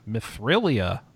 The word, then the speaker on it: Mithrilia: It's a 2D action RPG set in the fantasy world of Mithrila. – Mithrilia. – Oh, my bad. – Yeah, gotta get that extra syllable.